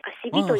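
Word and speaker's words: 馬酔木: アセビと言います。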